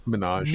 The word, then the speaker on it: Minaj: Minaj.